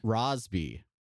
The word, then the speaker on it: Rosby: But anyway, Sarah Carbiner and Erica Rosby. – Yeah. I got it. – It rhymes with Losby.